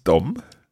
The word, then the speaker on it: dem: But now you're saying that we've got another word that's spelled D-E-M. – Yep. – That's still pronounced dom.